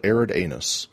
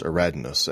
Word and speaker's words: Aridanus: Yeah, as we move into Sector Aradness, I looked it up, but it is spelled Arid Anus. It is pronounced Aradness, and it is, it's not Arid at all.